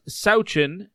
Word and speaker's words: Sawchyn: Gracyn Sawchyn from the Seattle Thunderbirds, and it is pronounced Sawchyn and not Sawchyn.